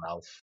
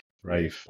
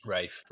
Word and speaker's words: Ralph: is it Ralph or Rafe? I don't know how he likes to pronounce his name. I call him Ralph. – Rafe.